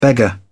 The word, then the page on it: beggar: British English